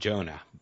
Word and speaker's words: Joanna: And how did he marry his wife, Joanna? He was in South Africa. They pronounce her name Jonah there.